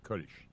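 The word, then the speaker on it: Kölsch: Kolsch. It's not O and it's not A. It's that thing that happens to an O if you put two dots over it in German. Kolsch.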